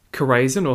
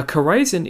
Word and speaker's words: Chorazin: So these are two towns, Chorazin, or sometimes pronounced Chorazin, and Bethsaida.